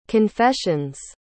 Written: confession's

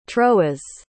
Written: troas

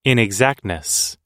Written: inexactness

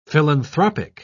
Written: philanthropic

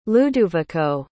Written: luduvico

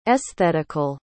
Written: esthetical

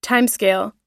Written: timescale

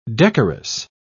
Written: decorous

